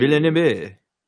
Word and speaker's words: Villeneuve: Denis Villeneuve. I'm never going to say it right. Denis. – I don't have no idea how to pronounce his last. Denis Villeneuve. – Villeneuve.